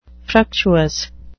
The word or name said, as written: fructuous